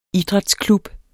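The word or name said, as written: IDRÆTSKLUB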